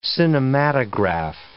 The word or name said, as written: cinematograph